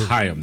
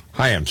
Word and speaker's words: Chaim: It's pronounced Chaim, though, I think. – Chaim, sorry.